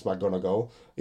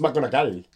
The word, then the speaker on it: McGonagall: But, like, for example, McGonagall, I don't pronounce McGonagall. It's McGonagall.